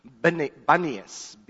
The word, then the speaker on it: Banias: And then it became known as Caesarea Philippi, and then eventually later it became known as Panias, P-A-N-I-A-S. And because of the way it pronounces in Aramaic, It was a tough word to say, so it actually got translated more recently into Banias, B-A-N-I-A-S.